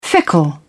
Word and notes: fickle: British English

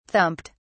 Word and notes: thumped: British English

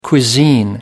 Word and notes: cuisine: American English

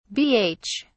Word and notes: bh: British English

